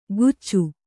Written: ಗುಚ್ಚು

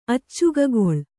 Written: ಅಚ್ಚಗಗೊಳ್

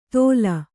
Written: ತೋಲ್

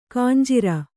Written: ಖಂಜರಿ

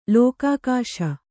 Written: ಲೋಕಾಕಾಶ

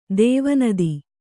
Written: ಧ್ವನಿತ

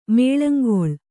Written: ಮಾಳಂಗೊಳ್

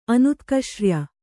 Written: ಅನುತ್ಕಷ್ರ್ಯ

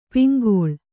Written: ಪಿಂಗಳ

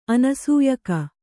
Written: ಅನಸೂಯಕ

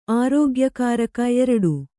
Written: ಆರೋಗ್ಯಕಾರಕ

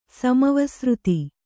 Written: ಸಮವಸೃತಿ

